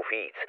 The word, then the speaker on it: Viets: Spreche ich mit der Detektei Onno Wietz, die mit Dannewitz zusammenarbeitet, den Anwalt? – Das ist korrekt. Das V spricht man aber wie F. Und natürlich weiß ich, wer Sie sind. – Ach so, Fietz.